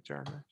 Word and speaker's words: Jarmusch: I'm gonna say his name, Jarmusch. Let's say it right, Jarmusch. – That's how I say it.